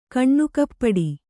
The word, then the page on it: ಕಣ್ಣುಕಪ್ಪಡಿ: Kannada